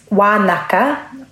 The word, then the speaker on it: Wānaka: My understanding is that, yes, it's Wānaka, which is the Ngaitahu pronunciation for Wānanga. In Ngaitahu, a lot of our NGs are pronounced as Ks.